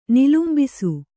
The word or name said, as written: ನಿಲುಂಬಿಸು